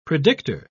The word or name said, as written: predictor